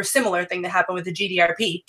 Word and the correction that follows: GDPR: to a lesser degree. – GDPR. But this shit can never say that right. Yeah, GDPR.